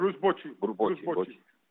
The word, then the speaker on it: Bochy: ¿Bochy o Bucky? ¿cómo se pronuncia, por favor? – Bruce Bochy.